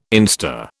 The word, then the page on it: instar: British English